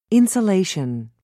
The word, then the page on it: insolation: British English